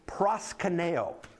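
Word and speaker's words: Proskuneo (προσκυνέω): Proskuneo. Say that. Proskuneo. That didn't really roll off your tongue yet, did it? Proskuneo. That would be the Greek term, or that's the Greek pronunciation of that word.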